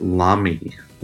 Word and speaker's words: Lamy: L-A-M-Y. In German, you pronounce it Lamy.